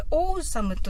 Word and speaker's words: awesome: なんですけれども、これ、オーサムと読みまして、 A から始まるんですけど、あの、意味合いとしては、このオールモーストとかありますよね。